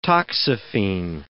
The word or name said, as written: toxaphene